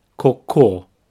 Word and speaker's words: cocô: Both syllables have the same sound, ko. However, the stress is at the end, cocô cocô.